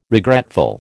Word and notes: regretful: American English

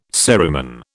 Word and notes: cerumen: British English

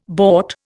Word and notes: bort: Indian

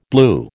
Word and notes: blew: American English

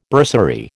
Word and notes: bursary: American English

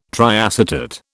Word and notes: triacetate: British English

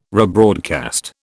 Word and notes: rebroadcast: British English

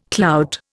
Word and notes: clout: Indian

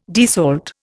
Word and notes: desalt: Indian

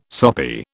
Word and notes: soppy: British English

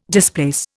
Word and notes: displace: Indian

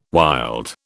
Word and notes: wild: British English

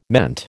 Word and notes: meant: American English

